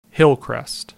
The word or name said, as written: Hillcrest